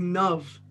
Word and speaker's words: Nav: So my name is pronounced Nav, and I just used to never correct anybody.